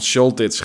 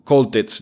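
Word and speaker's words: Choltitz: Ik heb hem de vorige keren steeds van Scholtitz genoemd. Maar ik kwam erachter dat die Duitsers die CH meer als een soort K uitspreken. Van Koltitz dus.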